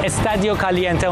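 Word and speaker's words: Estadio Caliente: استادیو کالینته اونطور که خود مکزیکی‌ها تلفظ میکنن.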